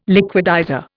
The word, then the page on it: liquidizer: Indian